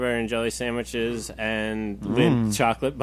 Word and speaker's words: Lindt: Lindt. I said it, like, the D is silent.